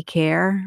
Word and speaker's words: Kaehr: And I've got another one at Shelley Kerr.